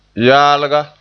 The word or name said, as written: yaalga